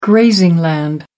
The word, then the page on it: grazing: American English